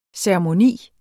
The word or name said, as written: ceremoni